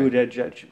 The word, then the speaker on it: Buttigieg: Alltså, sen var det någon som hittade på att det är boot edge edge. – Okay. – Boot edge. Okay. Boot edge edge.